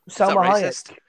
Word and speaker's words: Salma Hayek: Salma Hayek.